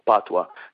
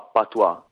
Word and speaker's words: patwa: Esatto, che si chiama, chiamato, diciamo, patwa, Jamaican patwa. Patwa è, diciamo, come lo pronunciano i giamaicani, ma la parola viene da una parola francese che si chiama patois, e patois appunto vuol dire miscuglio.